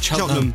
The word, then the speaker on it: Cheltenham: The people of Cheltenham say Cheltenham.